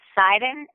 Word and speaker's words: Seiden: My last name is Seiden. It's spelled S as in Sam, E-I-D as in dog, E-N as in Nancy.